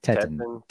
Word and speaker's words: Teton: I definitely pronounced that wrong. I was looking at it as Tenton. – It could be Tenton. I don't know how to read. – Teton. T-E-T-O-N. Teton.